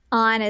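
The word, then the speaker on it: An: Yeah, they pronounce it On. – That makes sense since it's spelled A-N.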